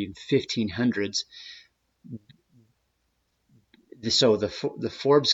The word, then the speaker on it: Forbes: And in this case, I'm going to use the example of the Forbes, which I believe at one time in history was pronounced Forbes, as in two syllables.